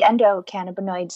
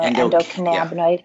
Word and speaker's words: endocannabinoid: Yeah, the endocannabinoid system or endocannabinoid. Yeah. It just depends on how you want that A to sound.